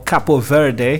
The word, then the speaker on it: Cabo Verde: Today we are in Cape Verde. That's where we've touched down, or Cabo Verde, the official pronunciation.